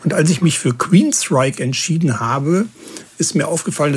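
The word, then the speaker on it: Queensrÿche: Also Queensryche. Und übrigens, seinen Vornamen schreibt man ja G-E-O-F-F, aber man spricht nicht Joff, wie viele Leute das tun, sondern Jeff.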